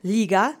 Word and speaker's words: liga: So, liga, league, let's say liga together three times. Accent is on the first syllable.